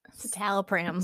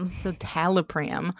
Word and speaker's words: citalopram: Citalopram. Citalopram.